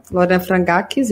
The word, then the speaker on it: Lorena Frangax: Hoje a gente vai conversar com a Lorena, Lorena Frangax, é assim que pronuncia? – Isso mesmo, Lorena Frangax.